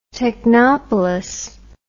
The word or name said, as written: technopolis